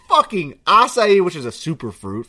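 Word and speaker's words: açaí: Talk to me about how bad acai bowls are for you. And I know I'm saying this on a big platform, and I'm probably saying it wrong. – I think you nailed that. Acai bowl.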